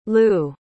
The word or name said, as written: lew